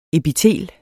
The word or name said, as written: EPITELER